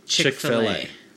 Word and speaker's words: Chick-fil-A: But is it spelled F-I-L-L-E-T? – No, it's spelled C-H-I-K hyphen F-I-L hyphen A. – And they say Chick-fil-A.